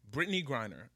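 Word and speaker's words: Brittney Griner: Brittany Griner, am I pronouncing that correct? – Yeah.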